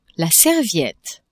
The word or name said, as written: serviette